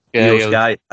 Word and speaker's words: Io Sky: So with the Bailey Dakota Kai, and is it Io Sky? Is that how you pronounce it? – Io Sky.